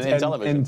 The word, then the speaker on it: Intellivision: Did I say it wrong? – You said Nintellivision. – Oh, I'm sorry, man. – I thought maybe he had some kind of weird amalgamation of an NES and Intellivision.